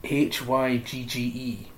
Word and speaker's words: hygge: H Y G G E.